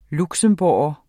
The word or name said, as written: luxembourger